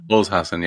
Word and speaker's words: Holzhausen: Holzhausen, yeah.